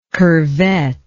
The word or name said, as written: curvette